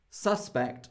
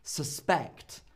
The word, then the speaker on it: suspect: Well, it is a verb, and so we should say it is always the person you least suspect. Suspect. The stress is on the second half because it's a verb.